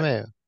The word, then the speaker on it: LeMahieu: Yeah, DJ LeMahieu. – Yeah, that a guy that was not tremendous career, awesome career. – Right, they not highly regarded. They traded him out of the system. Now, DJ LeMahieu